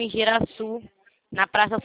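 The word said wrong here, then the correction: Igarassu: É Igaraçu, né? Falei errado. – É Igaraçu. – Pronto, certinho, tá?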